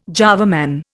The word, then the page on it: Java Man: Indian